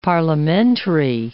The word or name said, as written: parliamentary